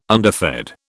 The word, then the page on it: underfed: British English